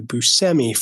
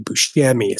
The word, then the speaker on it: Buscemi: And the reason why I waited to correct you guys on this is because he actually went by Steve Buscemi for the majority of his life until he went to Sicily and met his family and realized he's been pronouncing his name wrong his entire life. And it's actually Buscemi. So if you guys could just show some respect to the motherland and pronounce his name correctly moving forward, I'd really appreciate it.